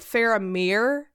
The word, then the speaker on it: Faramir: But the other two, so Faramir is the IR in that instance is pronounced with an EER, so Boromir, Faramir, and then UR is OOR, so OR, so like Dol Guldur Door.